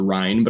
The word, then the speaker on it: Rein: And my last name, which actually secretly my last name is actually pronounced Rein, but I go with Rein.